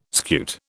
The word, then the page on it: scute: British English